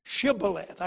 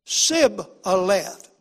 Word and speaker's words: Shibboleth: And he said, Sibboleth. Do you see that? He left the H out.